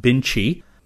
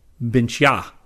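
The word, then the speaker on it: Bintje: Bart told me that the Dutch potatoes I was talking about in the August 2011 Science News are not pronounced Binchi, they are pronounced Binchya, A as in again.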